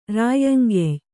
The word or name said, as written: ರಾಯ್ಯಂಗೆಯ್